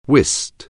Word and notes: whist: anglais

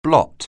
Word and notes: blot: anglais

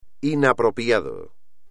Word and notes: inapropiado: espagnol

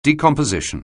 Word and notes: decomposition: anglais

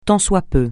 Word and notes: tant soit peu: francais

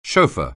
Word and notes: chauffeur: anglais

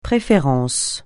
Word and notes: préférence: francais

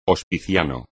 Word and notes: hospiciano: espagnol

